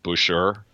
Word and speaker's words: Boucher: I really thought Chris Boucher was pronounced Boucher, but luckily you said it, before I could butcher it. But I want to come clean. I thought it was Butcher.